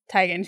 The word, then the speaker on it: Teigen: Says that she says it wrong. – Yeah, apparently it's Tegan.